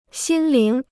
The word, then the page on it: 心灵: xīn líng